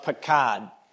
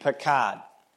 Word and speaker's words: Pakad: So the word is actually spelled, if you were going to use English letters, the word would be spelled PQD. And if you want to buy a vowel, buy the vowel A, because the word is pronounced Pakad, PQD, Pakad.